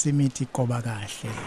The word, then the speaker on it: Imithi Gobakahle: Please forgive my pronunciation and correct it. Imithi Gobakale. – Yes, Imithi Gobakale.